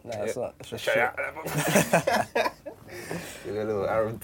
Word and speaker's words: Shia LaBeouf: How do you pronounce his name? – Shia LaBeouf.